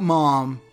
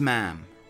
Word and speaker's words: ma'am: It is not ma'am, it's ma'am," rhymes with jam.